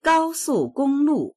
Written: gao1su4gong1lu4

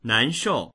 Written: nan2shou4

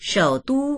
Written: shou3du1

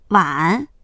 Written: wan3

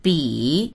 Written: bi3

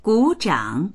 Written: gu3zhang3

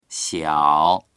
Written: xiao3

